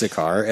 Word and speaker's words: Sakaar: It's pronounced Cutter. I think it's Sakaar.